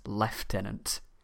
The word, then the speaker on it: lieutenant: But the big one, which some people are pretty gobsmacked about, is that we pronounce lieutenant as leftenant.